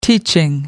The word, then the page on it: teaching: British English